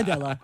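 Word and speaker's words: Adéla: You're saying it wrong. It's Adela.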